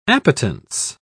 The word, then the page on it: appetence: British English